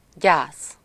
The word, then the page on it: peine: France; [pɛn]